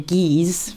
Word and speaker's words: Caius: Bonville and Keys is one college, and Keys is spelt rather strangely. – But it's spelt like the Latin name. – Well, yes, there you go, I suppose. Yeah. But you wouldn't say, what would you say, Keys for Caius? Bonville and Keys.